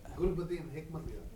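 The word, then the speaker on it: Gulbuddin Hekmatyar: At the time that this came up, the ISI was supporting, I can't pronounce these names, help me out, Gulbadar Hakmatyar. I can spell it, but Gulbadin Hakmatyar.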